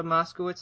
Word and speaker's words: Moskowitz: How do you say her last name? Issa Chandra Moskowitz, is that right? – Yeah, that's okay. Correct.